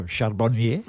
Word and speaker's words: Charbonnier: Not pronounced in your usual. – Oh, Charbonnier. – That's better, thank you.